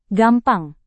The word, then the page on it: gampang: indonesian